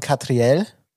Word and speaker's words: Catriel: Ist von, der wird wirklich so ausgesprochen, Catriel und Paco Amoroso.